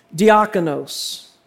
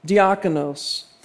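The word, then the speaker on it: diakonos (διάκονος): Now, it's an interesting word, that servant, in the original language, and it's pronounced diakonos, diakonos.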